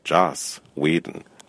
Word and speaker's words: Joss Whedon: Joss Whedon.